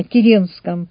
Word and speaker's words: Керенский: Ну и надо сказать, что здесь многое о Керенском говорится. Он сам настаивал на том, чтобы ударение делать на первом слоге.